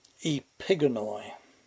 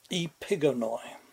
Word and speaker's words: Epigonoi: Epigonoi. I had to look it up to get the pronunciation right. Emphasis on the second syllable. Epigonoi.